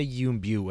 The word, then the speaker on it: Yuen Biao: Yuen Biao? How do you say that? His name? – I think... I say Yuen Biao, and I have that from... – Cynthia Rothrock says it that way.